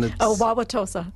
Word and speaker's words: Wauwatosa: Wauwatosa.